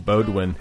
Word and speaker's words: Beaudoin: That is Sean Beaudoin. That's how you say it, Beaudoin.